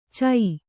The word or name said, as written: ಛ